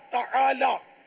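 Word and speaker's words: تعالیٰ: تعالی عین حلق سے نکالنا ہوگا, یہ سب کا نکلتا نہیں ہے. تو عین نکلتا ہے وہ بھی تالا بولتے ہیں, تالی زبر تا تالا لاک.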